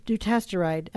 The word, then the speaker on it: Dutasteride: We stopped the Dutasteride. Am I saying that right? – Dutasteride at that time.